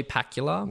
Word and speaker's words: Pakula: Pakula.